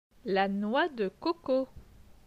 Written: La noix de coco

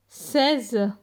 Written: 16

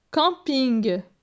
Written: Camping

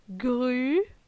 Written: Gru

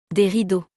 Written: Des rideaux

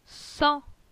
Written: Sans

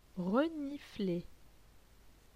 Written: renifler

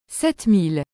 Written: 7000

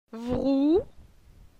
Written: Vrou

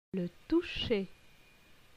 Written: Le toucher

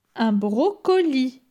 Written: Un brocoli

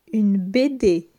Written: Une bd

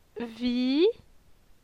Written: Vi

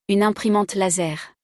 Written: Une imprimante laser

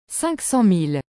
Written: 500000

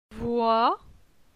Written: Voi

